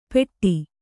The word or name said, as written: ಪೆಟ್ಟಿ